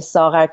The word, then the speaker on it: Saghar: Erica is my American nickname because my Farsi Saghar is very hard to pronounce. – It is. – I'm also on Twitter. If anyone wants to follow me on Twitter, it's actually Saghar Kasrai, S-A-G-H-A-R.